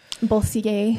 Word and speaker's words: Bossier: Bossier. Sorry if I'm not saying it right. Bossier, Louisiana. – Bossier.